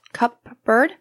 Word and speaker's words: cupboard: Cupboard? – They're pronouncing the P. Instead of it being silent. I mean, you don't really pronounce the P in that word.